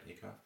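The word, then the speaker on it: Ackoff: Did I say that right, Acuff? – Yes, yes.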